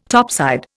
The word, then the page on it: topside: Indian